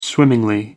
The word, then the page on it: swimmingly: American English